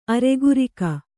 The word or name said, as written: ಅರೆಗುರಿಕ